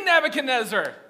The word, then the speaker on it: Nebuchadnezzar: How do you say that? King Nebuchadnezzar. Exactly, just rolls off the tongue.